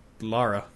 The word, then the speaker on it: Lara: It's L-A-R-A. – It's Lara. – Yeah, Lara. – Yeah. – It's like Laura, but Lara. It's the... – Lara as L-A-U.